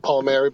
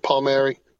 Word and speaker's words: Palmieri: For my players, a lot of them have a hard time pronouncing Palmieri, but most people call me Coach Palmieri.